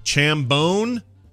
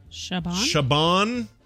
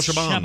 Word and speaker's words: Chabon: There's no M in there. – Oh. – Chabon? – But I don't know how to say it anyway. It could be Chabon, Chabon. – Shaved bone. – Michael Chabon. – Chabon. Let's say Michael Chabon.